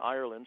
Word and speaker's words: Ireland: I'm on Twitter, so my title there, my Twitter name is Dr. David Ireland, and it's Dr. David and then Ireland, spelled like the country.